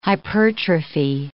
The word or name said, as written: hypertrophy